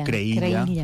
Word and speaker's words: Creïlla: O Creïlla, pronunciada a la valenciana.